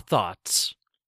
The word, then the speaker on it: though: Thaw thoughts. Thaw those thoughts. Number two, though. English is fun, though it can be difficult to learn sometimes. Though. Though rhymes with grow.